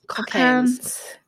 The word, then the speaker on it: Cockaigne: Cocaines. It's spelled, so just to let you guys know, it's spelled C-O-C-K-A-I-G-N-E. – Yeah. I YouTubed it before this and she did say it was like cocaine.